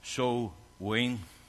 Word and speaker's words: Samhain: Another name for him was Shaman, pronounced So-ween.